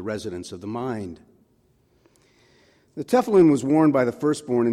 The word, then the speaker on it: tefillin: For your notes, it's spelled T-E-F-I-L-L-I-N, tefillin, on their right arm and on their forehead between their eyes.